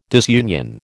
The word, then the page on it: disunion: American English